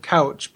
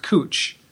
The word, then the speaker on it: Couch: There's also a street that's spelled couch, but everyone, it's pronounced cooch.